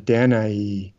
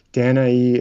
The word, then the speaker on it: Danae: and this is the story of Danae. Or I've seen it pronounced different ways. I've seen different professors pronounce it different ways. Danae, it's spelled D A N A E, and usually the E has two dots over it.